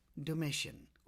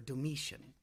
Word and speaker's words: Domitian: and are about to meet Domitian, or Domitian, according to what teacher I had as to how it's pronounced.